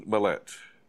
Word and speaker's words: Millette: But Susan Millette.